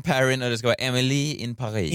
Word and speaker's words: Emily in Paris: Ja, Netflix gick ut med en rättelse att folk säger fel. – Ja, jag vet. Lite som jag tidigare uttalade Erin Perrin. – Exakt så. – Ja, Emily in Paris. – Ja, så ska det vara, ja.